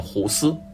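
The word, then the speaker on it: Guus: 希丁克其实是姓，他的名字按照荷兰语的发音应该是念胡斯，然而澳大利亚的球迷呢却不吃这一套，他们任性的用英语的发音来称呼他，管他叫古斯，而 Goose 就是大鹅的意思。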